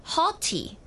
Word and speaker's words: haughty: 那除此之外呢，也有一个比较少见一点的词啊，它呢就读作 haughty，有点像 naughty 一样的，它的拼法也是差不多的。